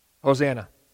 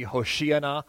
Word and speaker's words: Hosanna: This word Hosanna in Hebrew, you pronounce it a little bit differently. You'd pronounce it things like maybe Hoshiana or something like that.